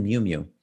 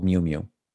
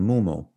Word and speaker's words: Miu Miu: It's called Miu Miu. You know, he made fun of the name, which of course, how could you not? Customers couldn't pronounce it for years. They kept saying Moo Moo.